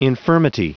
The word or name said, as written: infirmity